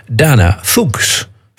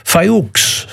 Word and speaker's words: Fuchs: Ze zit bij een Duits label, bij Roof, en dan ben je heel sterk geneigd om het uit te spreken als Dana Fuchs. Fuchs. Zo schreef je het, F-U-C-H-S. Maar als je dat dan opzoekt op in Wikipedia, dan staat er keurig netjes Dana Fuchs, pronounced as Fayouks.